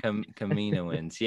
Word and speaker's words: Kaminoans: How you say it? – Kaminoans.